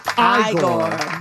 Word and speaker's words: Igor: It's pronounced Igor.